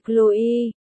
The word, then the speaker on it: Chloe: Dành cho bạn trẻ nào không biết đọc tên nữ minh tinh Chloe nhé. Tên của cô nàng đọc là cô. Lô, e Chloe, con tác giả chết tiệt.